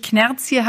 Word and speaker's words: Knärzje: Also die erste Frage, die ich habe, ist natürlich Knerz hier. Habe ich das richtig ausgesprochen? Das ist ja hessisch, ne? – Absolut richtig. Wirklich.